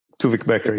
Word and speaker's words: Tuvik Becker: Do I spell your name right, Tuvik Becker? – Tuvik Becker. Yeah, that's right. Spelling and right pronunciation.